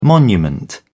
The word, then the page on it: monument: British English